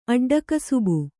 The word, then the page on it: ಅಡ್ಡಕಸಬು: aḍḍakasabu